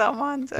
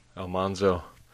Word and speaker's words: Almanzo: Is that the way you say his name? Is it Almanzo? – I think so. – Alfonso? Was that his name? – No, it's Almanzo. – Yeah, Almanzo.